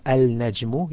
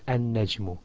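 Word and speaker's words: an-najmu (النجم): You either pronounce the al completely or you join the lam into the first letter of the word after it, such as instead of al-najmu you said an-najmu.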